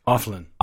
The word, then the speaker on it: Oefelein: God, I can't say his name. O-E-F-E-L-E-I-N? – Ofelin. – Ofelin.